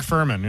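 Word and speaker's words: Fuhrman: Yesterday, we learned that Mark Farman, how do you say his name? Farman? That guy from the O.J. Simpson trial. – Yeah, the cop, right. – Yeah, he died. – Mark Furman.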